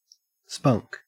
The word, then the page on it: spunk: Australian English